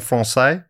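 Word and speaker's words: Français: Over the following centuries, they happened over other parts of En Francais. Is that right? Am I saying that right? – Yes, yep, correct.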